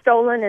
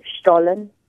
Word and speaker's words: Stollen: Well, Stollen is how you say it here. It's over in Germany, it's pronounced Stollen.